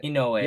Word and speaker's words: Inoue: Inoue? How do I pronounce that? – Inoue.